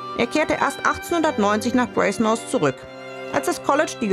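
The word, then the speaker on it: Brasenose: Es wird so ausgesprochen, wie es geschrieben wird, Brasemouth.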